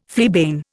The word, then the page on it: fleabane: Indian